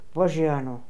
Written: Vojiano

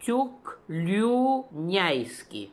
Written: Tookloonian

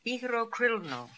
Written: Etherokrilno

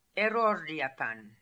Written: Erordiapan

